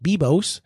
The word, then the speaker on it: Biblos: It's spelled B-I-B-L-O-S, but it's pronounced Bibos.